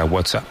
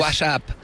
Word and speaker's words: WhatsApp: WhatsApp. – WhatsApp. No, peor aún, con acento en la primera.